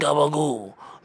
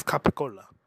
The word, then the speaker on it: capicola: Where the fucking gabagool? The gabagool. The gabagool. – You know gabagool is spelled, it's spelled C A. Yeah, gabagool is spelled C. C-A-P-P-I-C-O-L-A. It's capicola. Gabagool.